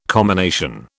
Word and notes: commination: British English

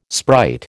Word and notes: sprite: American English

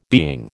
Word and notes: being: American English

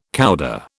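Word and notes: coudé: British English

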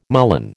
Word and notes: mullein: American English

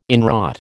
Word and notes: inwrought: American English

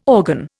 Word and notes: organ: Indian

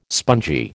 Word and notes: spongy: American English

